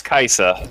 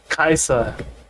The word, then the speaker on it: Kajsa: No, it's Kaisa. It's spelled K-A-J-S-A. – Kaisa. – Yeah, so the I kind of acts like a J, but that's how they pronounce it in Kaisa. – Scandinavian countries.